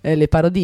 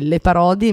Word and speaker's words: Le Parodi: l'altra volta ve l'avevo annunciata con un accento francese, Le Parodi invece no, è Le Parodi, me l'ha detto lei, Sole, è una persona molto molto molto carina, un artista molto interessante con cui abbiamo chiacchierato qualche giorno fa.